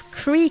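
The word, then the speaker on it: croí: Croy Croga betyder. Fast jag tror faktiskt att det ska uttalas Cree Croga om jag förstår uttalsguiden rätt. Cree eller Croy då är hjärta och Croga är modig.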